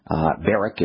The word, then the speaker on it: Barrack: You can reach Ben also by Googling Ben Barak, B-A-R-R-A-C-K. Barak is how Ben, I think, pronounces his name, but it's spelled B-A-R-R-A-C-K. Googling it